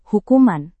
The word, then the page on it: hukuman: Indonesian